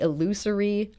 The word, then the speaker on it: illusory: Tell us about the illusory, I'm sure I've mispronounced that, truth effect. – I think you said that right.